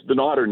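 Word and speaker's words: Dunnottar: Is it Dunnetar or Dun Otter? – You know, it's Dun Otter normally. That's what most people call it. But you know what? We don't care what you call it as long as you enjoy it when you come out. – Okay, so Dun Otter. So we're jotting that down phonetically. Dun-otter. And forgive our ignorance on this,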